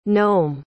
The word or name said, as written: noam